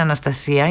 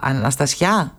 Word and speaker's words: Αναστασιά: Ας πούμε δεν είναι Αναστασία, είναι Αναστασιά. Αναστασιά, ωραία.